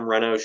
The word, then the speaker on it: Renno: My Instagram is Adam Renno Shooting. That's R-E-N-N-O.